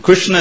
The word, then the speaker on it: Krishna: And not exactly, Krishna is actually, you know, the orthodox pronunciation. Is that the R, the S, and the A are cerebrals, and the N are cerebrals, which you should pronounce with the tongue on the top of your mouth, like Krishna.